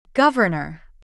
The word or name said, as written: governer